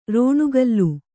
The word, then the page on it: ರಣಕಳ: kannada; raṇa kaḷa